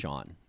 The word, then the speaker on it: Sian: Her first name is S-I-A-N, and I want to say that's like a Sean sort of sound. – I think it's pronounced Sean. – Yeah, Sean Clifford is her name.